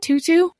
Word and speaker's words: Tutu: Is that how you pronounce it? Tutu? – Yes. The Archbishop Desmond Tutu. Yes.